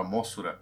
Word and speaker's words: Mosura: E isso é legal porque Mossura é, é, é a pronúncia japonesa de Mofra, né? o japonês ele fala Mosura,